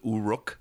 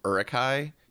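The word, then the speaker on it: Uruk-hai: Pat also kept on pronouncing everything as Uruk instead of Urukai.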